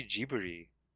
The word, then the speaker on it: Ghibli: But it's supposed to be Ghibli. – It is. – Yeah. – That's the katakana is clearly Ghibli.